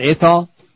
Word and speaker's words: Athol: Messier from his duties in Athol and Petersham after a lawyer told the diocese, am I saying that right, Athol? Ask the beast, Athol. He's from, how's what it is spelled? It? – A-T-H-O-L. – Athol? – He don't know.